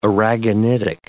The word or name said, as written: aragonitic